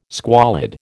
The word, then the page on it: squalid: American English